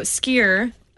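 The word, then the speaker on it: skyr: So skier, which I wrote in my notes to pronounce it like a skier, like a downhill skier, so because it's S-K-Y-R, and it was brought from Norway to Iceland more than 1,100 years ago.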